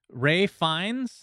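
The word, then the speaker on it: Ralph Fiennes: Ray Fiennes, I believe, is how he likes to pronounce his name.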